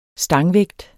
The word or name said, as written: stangvægt